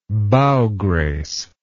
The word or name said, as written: bow grace